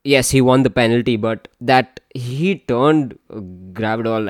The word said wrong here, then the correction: Guardiola: like upside down for that third. – Guardiola. – Yeah, sorry. I always get his name wrong.